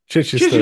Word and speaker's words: Chichester: And I have the absolute pleasure of speaking with all the way from, let me get this right, is it Chichester? Is that how I pronounce it? – Chichester in the UK.